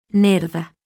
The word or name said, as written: n